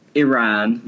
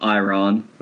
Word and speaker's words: Iran: Iran, not Iran, and Morocco, not Morocco.